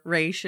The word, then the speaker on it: Ra's: Yeah, well, he calls himself Raish. – I know. I know. I've always thought of him as Ra's al Ghul because that's how it's spelled. But whatever, Raish it is.